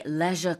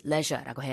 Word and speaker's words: leisure: 여기서는 coping with High leisure costs라고 했습니다. 이 leisure라는 발음도요, 영국에서는 우리나라랑 똑같이 leisure라고 해요. – 네. – 근데 미국에서는 leisure? – Yeah, leisure. – leisure라고 하죠. 발음이 좀 다른데, 네, 그래요.